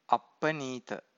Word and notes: appaneeta: Pali

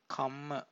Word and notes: kamma: Pali

